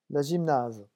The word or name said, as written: la gymnase